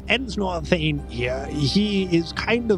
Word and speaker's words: Ensnor: That individual, Ensnor Thane. – Nor Thane? – Ensnor Thane. – What? – Ensnor? – How do you spell it? – E-N-S-N-O-R, Ensnor Thane.